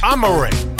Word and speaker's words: Amore: Let me make sure I'm pronouncing it right. Amore. Got a pair of tickets to see Amore. Concert will be Sunday, March 24th, 2024 at the Danforth Music Hall.